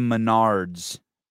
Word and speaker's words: Menards: Go to Menards, which rhymes with retards, and you can get their medium brands on sale for better quality.